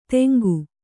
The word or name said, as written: ತೆಂಗು